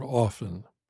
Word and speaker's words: often: In the last sentence, for example, listen to how I pronounce the word often. Do you hear a T sound? I hope not, because I am pronouncing it like often. The T is written but not spoken. Often, often, often.